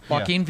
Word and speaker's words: Joaquin: So Joker with is it Joaquin? Is that how it's pronounced? – Joaquin. Yeah.